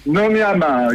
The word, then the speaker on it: Nomi Hamar: Bah ouais, justement, Lucenzo qui revient en force avec son nouveau single Nomi Hamar. Je le dis bien ou pas? Nomi Hamar, c'est ça? – Nomi Hamar, exactement.